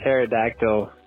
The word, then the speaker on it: pterodactyl: My joke is why can't you hear a pterodactyl going to the bathroom? Because the P is silent.